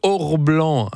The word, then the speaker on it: Aurblan: Oui, je vous recommande Orblan. Alors, je ne sais pas si je le prononce bien, mais ça s'écrit A-U-R-B-L-A-N.